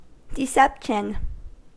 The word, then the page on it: deception: American English